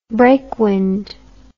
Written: breakwind

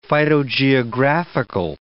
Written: phytogeographical

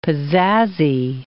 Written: pizazzy

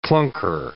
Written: clunker

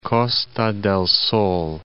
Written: Costa del Sol

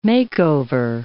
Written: makeover

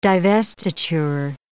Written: divestiture

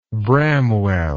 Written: bramwell

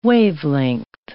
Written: wavelength

